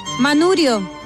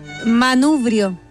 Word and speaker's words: manubrio: No se dice manurio, el del auto. – ¿Cómo es? – Manubrio.